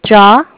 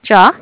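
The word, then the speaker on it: Jia: Oh, it's Jaw with Bank of Cardiff. – Can you pronounce your name? – Sure, it's J-I-A, Jaw.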